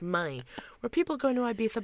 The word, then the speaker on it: Ibiza: Also, I refuse to not do the lisp. – The lisp. You have to. That's the authentic pronunciation. Ibiza. It's Ibiza.